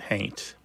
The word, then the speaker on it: haint: Somebody might spell it haunt, but pronounce it haint. – But definitely a long tradition. My Aunt Mazo used to talk about that house as hainted.